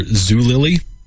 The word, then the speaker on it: Zulily: Worked for the online retailer Zulily. Am I pronouncing that right? – Yes.